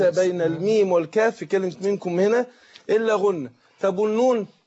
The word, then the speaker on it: منكم: فهنا لما أجي أنطق الإخفاء، لما أجي أقول منكم، يبقى أنا إيه؟ بنطق غنة بين الميم والكاف، والغنة مقدارها ايه؟ حركتين.